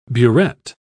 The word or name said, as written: burette